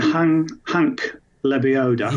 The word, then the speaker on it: Lebioda: We mentioned Hank Lebioda. – Yes. – Have I pronounced that correctly? – Yeah, you have, yeah.